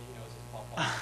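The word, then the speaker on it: Pau Pau: If you want to look it up, it's spelled P-A-U space P-A-U. It's like Paw Paw.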